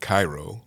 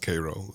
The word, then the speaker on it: Cairo: I am originally from South Georgia, a little small town called Cairo, spelled like Cairo, but pronounced Cairo, known for syrup, believe it or not.